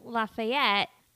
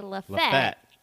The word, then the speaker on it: Lafayette: I also like that you pronounce it Lafayette. I know some places they would say Lafette. And I'm like, wait, you're missing a couple letters in there.